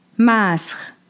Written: Masx

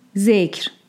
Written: Dhekr

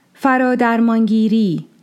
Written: Faradarmāngiri

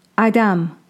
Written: ᶜAdam

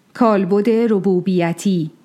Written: Robūbiyyati

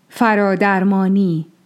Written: Faradarmāni

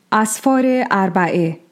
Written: Asfār-e Arbaᶜeh

